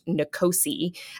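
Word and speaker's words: NCOSE: The acronym is pronounced NCOSI.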